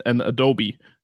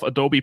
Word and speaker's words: Adobe: I've heard people say Adobe. I've heard people say Adobe, and of course Adobe. So I think it took me until I was 17 till I realized that's how you pronounce it correctly.